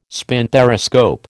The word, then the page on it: spinthariscope: American English